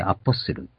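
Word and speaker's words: apostle: 英語でアポスル。 T が抜けて、発音から抜けてアポスルと言います。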